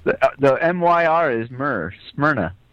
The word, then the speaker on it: Smyrna: Serena? Smyrna? I don't know. – Smyrna. – Smyrna? – Yeah. – Oh, my gosh. – You couldn't pronounce Smyrna? – No.